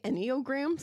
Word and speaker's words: Enneagram: It's Enneagrams. Did I say it right? – Did you say Enneagrams? – No, I didn't.